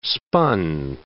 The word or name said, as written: spun